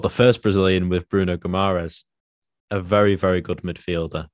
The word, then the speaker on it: Guimarães: And we'll go to the Brazilian, Bruno Guimarães. Is that how you pronounce it, I think? – Yes. – Guimarães. Yes.